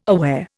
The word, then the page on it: aware: Indian